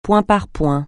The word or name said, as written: point par point